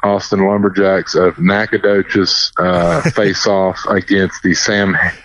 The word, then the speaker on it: Nacogdoches: Nacogdoches has a lot of letters in there that don't come into play whatsoever.